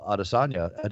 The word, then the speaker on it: Adesanya: Did I say his name last correctly? – You'll say Adesanya. It right on Saturday, and I am in no way prepared to tell you how to say it right now. – I will say it correctly on Saturday or Friday night. – I think you got it right, to be honest with you.